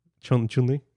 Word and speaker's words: Chun-Li: Is it Chun-Li or Chun-Li? – No, like how it's spelled. – C-H-U-N. – There's no G, right? – Yeah. No, it's C-H.